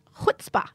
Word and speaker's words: chutzpah: Such a lesson in chutzpah. Is that how you say it? – It is. That's a Yiddish word.